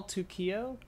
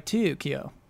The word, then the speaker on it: Tokyo: But with two, like Tokyo. – Yeah, but in Japanese, the to is a long O, so it basically it's like two O's anyways.